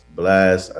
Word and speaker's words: Blxst: Yeah, so it's pronounced Blxst. I know it's spelled Blxst, but really it's just to be unique when you search it up on some brand, just thinking about branding wise.